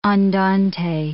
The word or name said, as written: andante